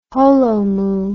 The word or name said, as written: holomuu